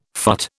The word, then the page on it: phut: British English